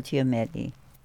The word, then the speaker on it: Giamatti: That I'm watching this show, Billions, and the attorney, major attorney, played by John Gio... How do you say it? – No, Paul Giamatti.